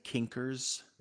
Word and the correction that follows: Knickers: You did, and it's Knickers, but that's okay.